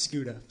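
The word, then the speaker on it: Skuta: It's Scooter.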